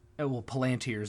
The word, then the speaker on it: Palantir: What we wound up doing is, once you beat the game, you unlock the they're called Palantir modes, and, well, Palantir is the movie pronunciation. It's actually probably Palantir, but Palantir.